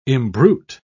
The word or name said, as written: imbrute